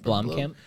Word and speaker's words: Blomkamp: Is that how you say his name? Blomkamp? – Blomkamp. – Mm-hmm. Yeah, Blomkamp.